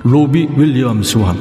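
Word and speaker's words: Robbie Williams: 로비 윌리엄성 이 발음이 왜 또 안 되냐? 로비 윌리엄스와 함께 이 노래를 부른 배우가 있죠.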